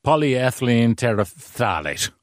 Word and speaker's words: polyethylene terephthalate: Now, correct me if I pronounce this badly, but it's polyethylene terephthalate. – Yeah, it's kind of polyethylene terephthalate.